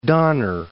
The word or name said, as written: Donner Pass